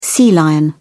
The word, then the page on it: sea lion: British English